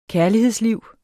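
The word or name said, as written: KÆRLIGHEDSLIVS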